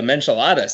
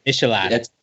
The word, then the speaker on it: Michelada: So that's the Menchilada, Steve. Is that, am I saying it right? – Michelada.